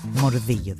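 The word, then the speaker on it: Mordillo: Μορδίγιο δηλαδή, όπως προφέρεται.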